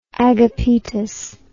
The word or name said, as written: agapetus